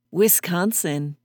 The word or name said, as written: Wisconsin